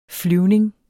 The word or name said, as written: flyvning